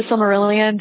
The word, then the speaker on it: Silmarillion: I haven't read The Silmarillion.